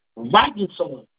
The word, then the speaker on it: Wagson: Como se pronuncia o seu nome? – Vagson. – Wagson.